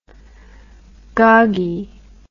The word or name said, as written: goggy